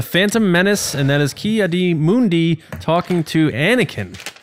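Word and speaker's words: Ki-Adi-Mundi: Ki Adi Mundi. – Yeah, no, it's... – You didn't write it right, but you said it right.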